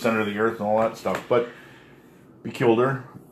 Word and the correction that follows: Pellucidar: I think it was called. – Oh, Pellucidar. – Pellucidar, thank you.